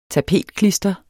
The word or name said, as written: tapetklister